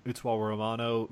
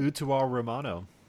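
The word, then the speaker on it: Utawarerumono: Utsuwa Romano. – Utsuwa Romano. – I'm not going to pronounce it the Japanese way because I can't pronounce my R's.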